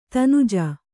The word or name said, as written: ದನುಜೆ